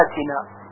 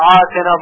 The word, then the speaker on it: آتنا: توجه دارید که در کلمه آتنا باید حمزه تلفظ بشه، ربنا آتنا، اگر گفته بشه آتنا با عین غلط هست، ربنا آتنا.